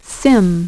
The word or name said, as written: SIMM